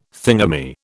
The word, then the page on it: thingummy: British English